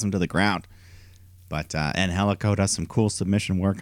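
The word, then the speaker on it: Angélico: I think it's Angelico. Yeah, it's a silent G.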